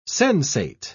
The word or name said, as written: sensate